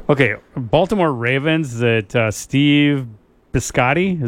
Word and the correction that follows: Bisciotti: is that how you pronounce that? You're Italian. – Yeah, it's Steve Bisciotti. – Bisciotti.